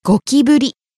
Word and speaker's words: ゴキブリ: ゴキブリ Cockroach. – ゴキブリり、ゴキブリ。 – Starting low and rising on the second syllable, き、staying high.